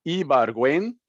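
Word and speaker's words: Ibargüen: I'm not sure where it stands, but at the very least, maybe you can tell us a little bit about Club America's Ibarguen, I think is how we pronounce it, right? – That's how I say it, yeah.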